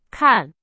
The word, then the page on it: 看: Chinese